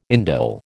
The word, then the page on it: indole: American English